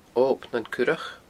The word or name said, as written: Ob nan Curach